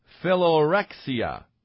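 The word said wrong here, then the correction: Philoxenia: I think I pronounced that incorrectly. It's Philonexia, Philonexia. Philonexia is a love of strangers.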